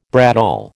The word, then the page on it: bradawl: American English